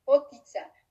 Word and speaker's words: potica: It's P-O-T-I-C-A. P-O-T-I-T-A. Potica. Because in Slovenian, we don't, it's for Slovenians, it's weird that you're, for example, pronouncing A like A, like I, because we pronounce A and E.